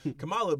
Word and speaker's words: Kamala: Kamala, because she is a black woman, I understand that.